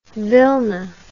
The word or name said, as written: vilna